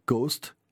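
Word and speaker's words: KOST: Le reste du temps, Coast, K-O-S-T, K-O-S-T pour le call letters, mais prononcé phonétiquement coast, comme la côte, sous-entendu ouest.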